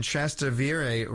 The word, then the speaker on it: Trastevere: Her relics were discovered in 821 by Pope Pascal I and moved to the Basilica of St. Cecilia in Trastevere, Rome.